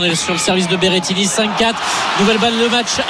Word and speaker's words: Berrettini: Berrettini.